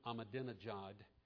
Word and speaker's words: Ahmadinejad: Iranian President Mahmoud Ahmadinejad, and let the record show that I did pronounce it correctly at least once today.